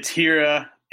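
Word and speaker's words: Hiura: What's your outlook on Keston Hira? How do I properly say his name? – It's Hira, and he, you know, he's extremely talented.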